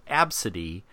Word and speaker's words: Abcde: spelled A, B, C, D, E. And it's pronounced Absidy.